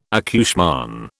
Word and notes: accouchement: British English

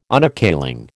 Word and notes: Unappealing: American English

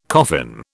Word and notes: coffin: British English

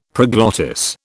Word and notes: proglottis: British English

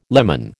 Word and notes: lemon: American English

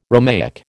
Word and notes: Romaic: American English

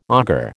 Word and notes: auger: American English

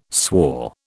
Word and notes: swore: British English

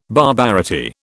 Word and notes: barbarity: British English

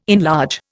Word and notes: enlarge: Indian

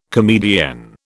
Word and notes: comedienne: British English

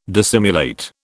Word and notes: dissimulate: British English

